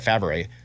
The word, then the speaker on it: Favre: It's how it's spelled. I know. It's like Brett Favre.